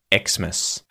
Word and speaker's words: Xmas: So you'll hear it pronounced as either just Christmas or potentially Xmas.